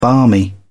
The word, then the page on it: barmy: British English